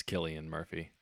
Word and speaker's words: Cillian Murphy: No, it's spelled with a C, but it's Killian Murphy.